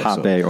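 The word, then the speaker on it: rapé: And I think it's spelled R-A-P-E, right? – Either one. – Okay. – Jape or rape. – Jape, rape.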